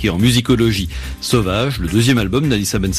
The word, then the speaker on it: Sovaj: Sauvage, il faut le préciser tout de suite, ça s'écrit S-O-V-A-J.